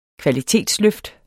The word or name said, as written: kvalitetsløft